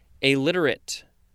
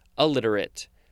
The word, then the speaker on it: alliterate: Next, we have illiterate, but it's actually pronounced alliterate. But it starts with an A.